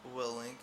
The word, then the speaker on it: Willink: Is it Jocko Willink? – Willink, yeah. – Willink. Okay.